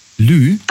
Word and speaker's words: Lieu: Lieu. Also Lieu, das schreibt man, also L-I-E und dann U.